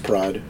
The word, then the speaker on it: Prades: It's pronounced Prades.